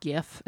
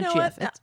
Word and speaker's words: GIF: It's a hard G, right? GIF. It's not GIF.